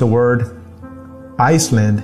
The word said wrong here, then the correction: island: instead of island.